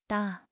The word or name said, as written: 搭